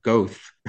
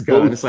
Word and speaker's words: Goethe: And then there was all these other strange people whose names I was, you know, mispronouncing, Goethe and Nitzka. And it's Goethe.